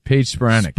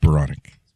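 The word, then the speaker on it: Paige Spiranac: I always want to say, is it Spironik or Spironik? – Paige Spironik. – Spironik. – Yeah, you always get in my head, make me think I'm saying it wrong. – How do I get in your head? I'm the one who said it right.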